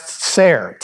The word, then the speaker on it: Tsair: Now, the word that Micah uses is a Hebrew word that's pronounced Tsair, T-S-A-I-R.